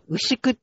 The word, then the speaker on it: 牛久: あ、なんて読むんですか？ – これ、牛久って読みますね、はい。